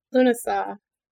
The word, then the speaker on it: Lughnasadh: Lughnasadh.